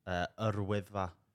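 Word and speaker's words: Yr Wyddfa: And how do you say Snowden? – Yrwyddfa. – No, you nailed it.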